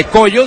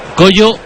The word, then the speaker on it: Kojo: Se va a Jetey y entra Pepra Opong, de nombre Collo, del cual ya hablamos en el anterior partido. Y luego sí es un cambio ofensivo. – Es Collo, no Pollo, ¿no? – Collo se dice, pero se escribe Cojo. K-O-J-O.